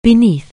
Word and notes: beneath: anglais

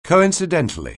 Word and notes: coincidentally: anglais

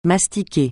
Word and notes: mastiquer: francais

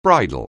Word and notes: bridle: anglais

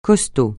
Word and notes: costaud: francais

